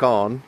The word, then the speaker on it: Avon: It's 10 o'clock now, and we've just come up from Loch Arn, which is obviously the correct pronunciation of Loch Avon, which these two guys let me say earlier on this morning.